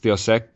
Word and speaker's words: Deus Ex: I was a huge fan of Deus Ex, or actually the way it's properly pronounced, I guess, is Deus Ex.